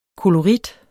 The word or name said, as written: KOLORITTERNE